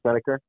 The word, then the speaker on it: Snedeker: Brandon Snedeker.